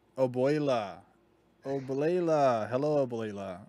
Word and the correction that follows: Abuela: How do you say it? – Abuela. – A boy la. Oblela. A ble la. – Ble. A boy. – Wait. – Abuela. – Oble. – Boy. La. Not oblela.